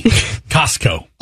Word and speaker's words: Costco: I say it with a silent T. Costco.